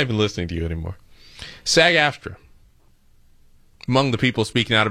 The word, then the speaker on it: SAG-AFTRA: SAG-AFTRA. Did I say that right? Yeah, I did say. Don't stop laughing at me. SAG-AFTRA.